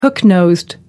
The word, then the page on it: hook-nosed: American English